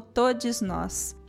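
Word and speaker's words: Todxs Nós: E aí se escreve todas com um X no lugar do ar, algo como Todos Nós. Acho que é assim que se fala. T-O-D-X-S. Nós.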